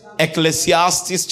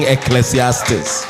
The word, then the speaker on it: Ecclesiastes: Ecclesiastes chapter 9 verse 11. Ecclesiastes. I know a lot of people cannot pronounce Ecclesiastes. So you should clap for me for pronouncing Ecclesiastes.